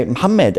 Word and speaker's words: Muhammad: How do you say your name in Arabic? I say, Muhammad. And they said, that's Muhammad.